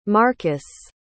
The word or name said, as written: marcas